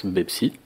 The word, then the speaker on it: Pepsi: Oui, parce que le P n'existe pas en arabe. Donc ça se prononce Pepsi.